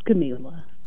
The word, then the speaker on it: Kamelah: Okay, you say a lot of people pronounce it Kamelah. What is your preference? – It's Kamelah.